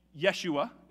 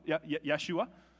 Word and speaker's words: Yeshua: In Hebrew, it is Yeshua, Yeshua.